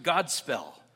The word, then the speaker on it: Godspell: It was a word, they pronounced it Godspell, which meant a good story or a good message.